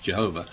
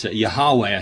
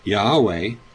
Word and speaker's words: Yahweh: Jehovah, some people, it's not pronounced Jehovah. But Yahweh, I think, is the current understanding, but I've no idea exactly.